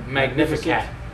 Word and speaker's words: Magnificat: The Magnificat. – Magnificat. – Y así lo dicen los católicos. – This is how it's said by the Catholics.